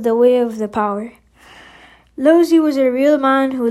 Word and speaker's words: Laozi: His name sometimes spelled Laozi too, L-A-O-T-Z-U, but it's pronounced Laozi, was said to be the author of a work called the Tao Te Ching.